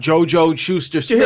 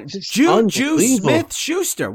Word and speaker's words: Juju Smith-Schuster: John Gruden last night. Oh, my God. JoJo Schuster Smith. – Did you hear Marvin Lewis today? Juju Smith-Schuster. What's the problem? – Easy.